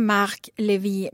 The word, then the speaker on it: Marc Levy: Och jag säger Marc Levy med franskt uttal för att han är faktiskt fransman.